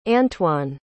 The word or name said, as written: antoine